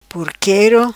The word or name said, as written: Porquero